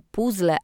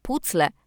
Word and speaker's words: puzzle: Jeśli ktoś mówi nie puzzle, a pucle, a wy od razu bierzecie tę osobę za lamusa, to najpierw sami zerknijcie do słownika języka polskiego PWN albo wielkiego słownika języka polskiego pod redakcją Piotra Żmigrodzkiego, w których wyraźnie wskazane jest, że obie formy wymowy są poprawne.